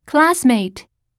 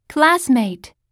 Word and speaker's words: classmate: Classmate. Classmate. – 日本語ではクラスメートだけれど、英語ではクラスの方を強く発音するよ。そして単語の最後はとではなくトと発音しよう。